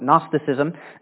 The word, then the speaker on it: Gnosticism: The G at the beginning is silent. Gnosticism, which taught that the deeds of the body cannot contaminate your spirit.